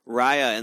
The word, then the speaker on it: Raya: There's also a, a movie um, that we've watched a trailer for a couple times um, was tell me if it if I say this right. Raya and the L- Last Dragon. – Raya and the Last Dragon. – Yes.